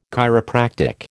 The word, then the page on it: chiropractic: American English